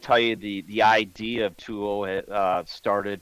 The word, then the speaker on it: Tuo: That's how it's pronounced. – Tuo. – T-U-O gear. And you guys are fairly new, correct?